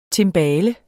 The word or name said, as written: timbale